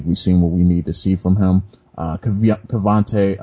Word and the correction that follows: Keaontay Ingram: uh, uh, I think I said that right. Keaontay Ingram, excuse me, the running back out of Texas.